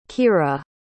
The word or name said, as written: kirra